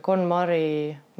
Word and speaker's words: KonMari: Äh, seda nimetatakse KonMari meetodiks, kas ma hääldasin õigesti? – ja KonMari meetod, korrastuskunst.